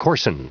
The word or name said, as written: coarsen